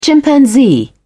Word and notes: chimpanzee: British English

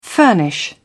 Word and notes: furnish: British English

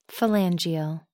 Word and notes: phalangeal: American English

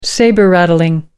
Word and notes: sabre rattling: American English

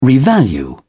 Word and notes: revalue: British English

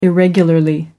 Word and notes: irregularly: British English

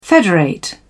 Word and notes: federate: British English

